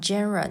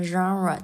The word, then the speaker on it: genre: 好，下面第一个词 genre 这个词是法语词，不念成 genre，念成 genre。